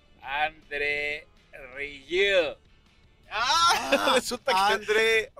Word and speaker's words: André Rieu: Métanse a YouTube, métanse a YouTube y pónganle André Rieu, así se escribe, A-N-D-R-E-R-I-E-U, Rieu. Este, how, do you pronounce it? – Rieu. André. André Rieu. – André Rieu.